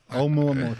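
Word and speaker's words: Oumuamua (ʻOumuamua): Oumuamua. How is it pronounced? Oumuamua. – I think Oumuamua. I think of a cow every time. – Right. Oumuamua. Thank you.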